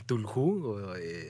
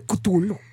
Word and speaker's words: Cthulhu: El Tulhu. – ¡Ay, ese es un mexicano! Según yo, soy la que lo digo mexicano, Tulu. Y este, Cthulhu.